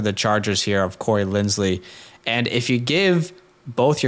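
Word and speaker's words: Linsley: Lindsley, by the way. I know we keep saying Lindsey. It's just so hard to say his name properly.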